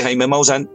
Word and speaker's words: Jaime Maussan: También vi que en los capítulos de la serie, de los expedientes secretos de Jaime Maussan, ahora sí lo dije bien, los expedientes secretos de Jaime Maussan, tocas el, el, el Popocatépetl, el volcán.